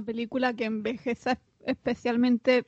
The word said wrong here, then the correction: envejezca: Envejeza o envejezca. Eso he dicho envejezca. Has dicho envejeza. – Pero porque he pronunciado mal.